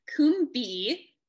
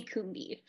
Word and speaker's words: Combe: It's spelled as comb with an E. And sometimes I say that, but if I could change it, I would add another O, but it's Chelsea Combe.